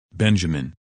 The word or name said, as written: Benjamin